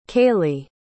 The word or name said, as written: kailee